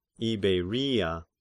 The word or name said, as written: Iberia